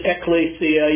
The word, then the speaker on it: ekklesia: And so we've been looking rather extensively then at this word, which would be pronounced in English, ekklesia.